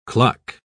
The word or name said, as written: cluck